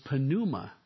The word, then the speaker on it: pneuma: And in the Greek, it is pneuma. You always pronounce the P when you put a P and an N together in the New Testament in Greek.